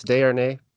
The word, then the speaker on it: Desharnais: That's pretty close. Desharnais. – Desharnais, yeah. So such a great memory, you know.